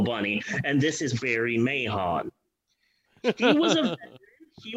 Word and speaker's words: Mahon: So a leading director in the world of Nudie Cuties was a guy by the name of Barry Mahon. – Yes. – I think the H in his last name is silent, but I believe that all letters matter.